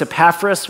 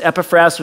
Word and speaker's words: Epaphras: I used to think his name was Epaphras, which that sounds like a little bit more normal to me, but apparently in the original, you know, pronunciation, it was Epaphras, which reminds me a little bit of sassafras, you know?